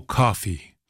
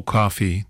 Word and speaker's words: Kauffey: And Mo Coffey, that's spelled M-O, separate word, K-A-U-F-F-E-Y, Mo Coffey is based in Guelph, Ontario, and so was my friend Champagne Charlie, a.k.a.